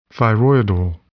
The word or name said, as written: thyroidal